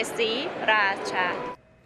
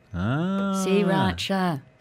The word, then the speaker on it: Sriracha: You omit the R. You just say Sriracha. – Sriracha. I am on InstaShop. Other delivery apps are available. Sriracha is available in 5 shops near me, 26 and a half dirhams.